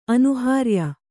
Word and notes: ಅನುಹಾರ್ಯ: anuhārya

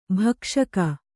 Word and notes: ಭಕ್ಷಕಿ: bhakṣaki

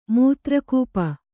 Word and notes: ಮೂತ್ರಕೂಪ: Kannada

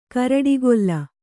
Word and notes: ಕುರುಡುಗಲ್ಲಿ: kuruḍu galli; kannada

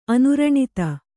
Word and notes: ಅನುರಣಿತ: anuraṇita

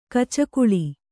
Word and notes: ಗಿಚಕಳಿ: gicakaḷi

